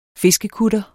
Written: fiskekutter

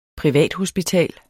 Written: privathospital

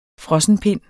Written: Frossenpind

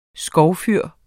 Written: skovfyr

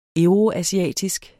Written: euroasiatisk

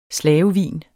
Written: slavevin

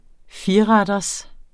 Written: fireretters

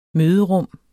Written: møderum

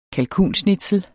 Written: kalkunschnitzel

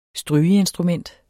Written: strygeinstrument

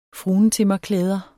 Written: fruentimmerklæder

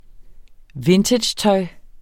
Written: vintagetøj

